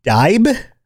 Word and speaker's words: Dibe: They've been worshiping Dibella, but they go by the name Dibe, D-I-B-E, I think is the pronunciation.